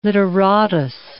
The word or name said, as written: literatus